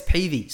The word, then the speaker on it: Peavy: Is it Peavy or Pevy? – Yeah, it's Peavy. So I get it Peavy all the time. So I guess the way, it is spelt Pevy.